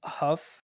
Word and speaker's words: HUF: We got this Godzilla X Huff. Is that how you say it? – Yeah. – HUF Huff.